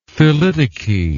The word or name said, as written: thelytoky